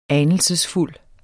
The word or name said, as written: ANELSESFULDT